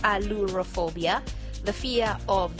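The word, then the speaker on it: ailurophobia: And what we are going to look at is the fear of cats, which is called allurophobia, which is spelled as A-I-L-U-R-O then phobia. So it is allurophobia.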